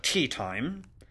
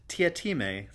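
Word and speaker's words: Teatime: "Mr. Tea Time?" – "It's pronounced Tiatime, sir," said Tea Time, with just a hint of reproach. "Everyone gets it wrong, sir."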